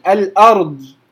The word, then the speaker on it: الأرض: اللي بينطق الضاد بغير استطالة أصلا، اللي بيقول الأرض، الأرض كأنها مقلقلة، الأرض هو جايبها من مخرج الدال، بس كل الموضوع ان هو بيرفع لسانه لفوق فبتخرج على انها دال مفخمه.